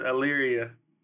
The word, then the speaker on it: Elyria: You're born in Elyria. Am I saying that correctly? – Yeah, it's Elyria.